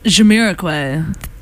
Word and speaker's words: Jamiroquai: And then we had a Maury pick, which was Feels So Good by, how do you pronounce this? – Jamiroquai. – Better than I would have ever said.